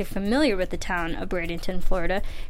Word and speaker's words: Bradenton: for this episode, Bradenton, Florida.